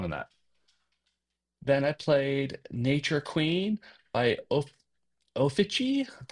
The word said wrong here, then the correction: Ofege: Probably mispronouncing it. It's Ofege.